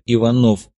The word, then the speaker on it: Иванов: Обещал ему большой любитель темноты товарищ Агосфер, он же Иванов, с ударением на втором слоге по-офицерски.